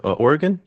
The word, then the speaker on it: Oregon: I think he's in Oregon. Did I even say that right as a Canadian? – Oregon? Yeah.